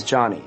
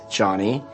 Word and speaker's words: Jony: My name is Johnny, spelled J-O-N-Y. – Oh. – And it's normal for someone to pronounce my name like Joni.